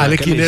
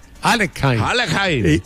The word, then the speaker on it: Alekhine: Y a Alekhine era, dice, pero pronuncie bien, Alekhine.